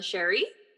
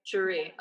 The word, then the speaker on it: Cherie: This is our third episode, and we are hosting the wonderful Melissa Cherie. Is that how you say your name? – Yes, Cherie. Yep. – Cherie, okay.